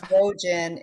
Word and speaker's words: Wojan: It's Wojan. It rhymes with Trojan.